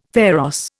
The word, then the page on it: pharos: Indian